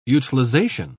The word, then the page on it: utilisation: British English